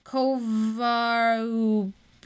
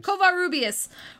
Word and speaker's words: Covarrubias: Fortunately, two of Houston's associates, Diego Rivera and Miguel Covarrubias, yeah, that's how you say it. Covarrubias went to bat for the director with the president of Mexico.